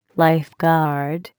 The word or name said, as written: lifeguard